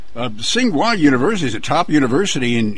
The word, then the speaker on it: Tsinghua: Yeah, Tsinghua, you pronounce it. Tsinghua, okay. – Yes.